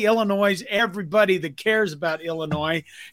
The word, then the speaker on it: Illinois: Did you just say Northern Illinois? – Yeah. – I don't know if you know this, but the S is silent. – No, not in my house, it isn't.